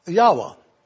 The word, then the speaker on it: Yahweh: These records indicate that in all likelihood, the name should be pronounced Yahweh.